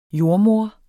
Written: jordemoder

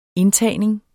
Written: indtagning

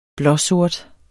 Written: blåsort

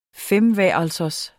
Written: femværelsers

